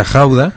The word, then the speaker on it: Gouda: Allà feien el Gouda, que és el Gouda, el Gouda, depèn com es pronuncia, sí. Es pronuncia Gouda, uh-huh.